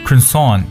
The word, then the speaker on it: croissant: croissant 这个词从发音来听呢就是法语的词 croissant C-R-O-I-S-S-A-N-T Croissant 我们在中文中可以直接拿音翻成为可颂，对不对？ Croissant 大家去像肯德基如果去吃早餐就能看到 Croissant，它也表示用羊角或者牛角包，就两边是尖一点的这种感觉。